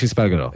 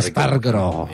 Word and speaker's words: Espargaró: Aleix Espargaró. – It's Aleix Espargaró. – You're not rolling your R's well enough. – No, I'm not. – Espargaró.